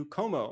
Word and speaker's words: Comeau: Como. My last name is a little funny. It's spelled C-O-M-E-A-U.